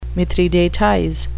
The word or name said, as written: mithridatize